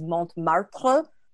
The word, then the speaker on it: Montmartre: I could not pronounce Montmartre, which is Montmartre.